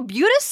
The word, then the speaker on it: Butes: is it Boots? Is it Butts? Is it Boutes? – How do you spell it? – B-U-T-E-S. – It might be just Butes. – You know, Butes sounds right. Yeah. – Yeah, it's Butes. – Perfect.